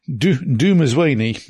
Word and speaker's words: Dumezweni: Dumezweni, that's it, who played UNIT's Captain Erisa Magambo in Turn Left and Planet of the Dead.